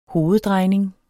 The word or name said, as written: hoveddrejning